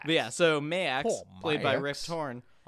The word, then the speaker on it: Maax: Mayax? – Mayax. Or Mayax. – Yeah, Mayax. Because I spelled it M-E-Y-A-X. – It's spelled M-A-A-X. – Max. Got it.